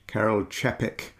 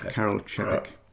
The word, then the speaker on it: Čapek: by a Czech writer, and I've discovered with the benefit of Wikipedia that his name should be pronounced Karel Čapek. The little duver over the sea means it's a soft C, not a hard C. So Karel Čapek. – Karel Čapek.